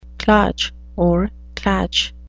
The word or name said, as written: klatsch